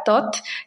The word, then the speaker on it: TOTH: TOT, que se escribe T-O-T-H, Compliance.